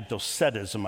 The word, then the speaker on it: Docetism: There's three isms: dualism, Gnosticism, and Doceticism. Actually, Docetism, I think, is actually how it's pronounced, but these three isms are what John is speaking into.